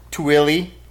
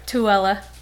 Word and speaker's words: Tooele: Tooele. – Tooele. And how do you actually pronounce that? Tooele. You just confused the hell out of me. – Tooele. – Tooele. – But it's T-O-E-L-E.